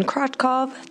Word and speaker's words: Krotkov: My name is Jasmine Krotkov. That's spelled K-R-O-T-K-O-V.